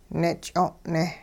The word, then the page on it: nich'o'ne: hupa